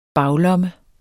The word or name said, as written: baglomme